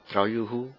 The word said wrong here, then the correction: Děkuju: Druyuhu, How do you say thank you? – Děkuju. – Děkuju.